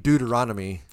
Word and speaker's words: Deuteronomy: Deuteronomy? Is that how it's, help me out. How do you pronounce it? – Deuteronomy.